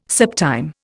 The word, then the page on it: septime: Indian